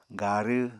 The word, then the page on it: garw: Apatani